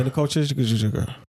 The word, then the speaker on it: Nicole Scherzinger: Yeah, Nicole Scherzinger.